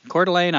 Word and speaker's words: Coeur d'Alene: Coeur d'Alene, Idaho.